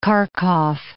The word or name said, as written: Kharkov